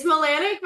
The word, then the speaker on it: Milanak: It's Milanak, but that's okay.